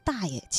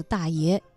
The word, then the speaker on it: 大爷: 在现场，他说：“ 对于叶广芩这本书呢，其实刚开始不太理解，呃，读的是‘ 耗子大爷起晚了’。等他把这本书读完之后，突然觉得，哎呀，好像是自己读错了。他不是‘ 耗子大爷起晚了’，而应该读成‘ 耗子大爷起晚了’。